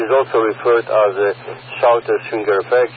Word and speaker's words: Schwinger: Yeah, that's the Schwinger effect. Schwinger, do I say it properly? S-C-H-W-I-N-G-E-R?